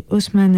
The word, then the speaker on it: Osman: C'est Osman Evjan, du coup Osman comme ça se prononce O-S-M-A-N, Evjan, E-V-C-A-N, Kocaeli, ça c'est l'adresse, Kocaeli 1, K-O-C-A-E-L-I 1, Nolu, N-O-L-U, F, Tipi, comme un tipi, Jezaevi, C-E-Z-A-E-V-I, ensuite A-7-21, Turquie.